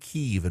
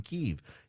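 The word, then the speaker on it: Kyiv: for so long, we in the West said Kiev, and that was the Russian pronunciation for the capital of Ukraine. The Ukrainians said Kiev, and much of the West has gone back to saying Kiev in recognition of Ukrainian sovereignty.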